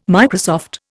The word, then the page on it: Microsoft: Indian